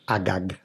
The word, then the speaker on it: Agag: You might read it Agag. – Agag, yeah. Agag. – Agag. – Agag. – But they would have pronounced it Agag.